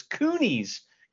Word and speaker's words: Cooney: I was like, Kino's wrestling or karate school, but it's Cooney's karate school.